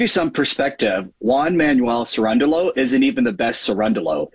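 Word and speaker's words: Cerúndolo: And somehow, some way, as a 32-1 underdog, Cirundolo, I believe is how you say his name, beats Sinner. I mean, Cirundolo is how you say his name.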